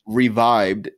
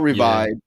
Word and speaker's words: REVIB3D: They're called Revived, and it's spelled R-E-V-I-B, the number 3 and then a D. Three. So the last E is a 3, but they're called Revived. Yeah.